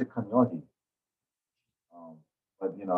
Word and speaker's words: Kaneohe: Yeah, I know what I pronounce it as. It's Kaneohe. But if you are Ma'a to that, if that's how you pronounce Kaneohe, that's you know, that's your kuliana.